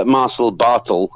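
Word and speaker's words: Barthel: So they put him up against Marcel Bartel, apparently is how they're pronouncing it, formerly Axel Dieter Jr. of Ringkampf, friend of our favourite Volta.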